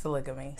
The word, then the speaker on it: sologamy: Am I saying it right? – I think it's sologamy.